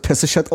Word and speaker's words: Pesichet: Also der Ägypter, der mir immer Bescheid sagt, wie altägyptisch ausgesprochen wird, der hat zu mir gesagt, es wird Pesichet ausgesprochen, weil sie war nämlich Ägypterin.